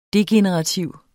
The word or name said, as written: degenerativ